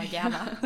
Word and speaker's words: Gerber: Und deswegen, vielleicht ist Gerber auch richtig ausgesprochen. – ja, stimmt. – Nicht Gerber, aber Gerber.